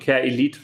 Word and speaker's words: careelite: So wird es geschrieben und careelite wird es ausgesprochen.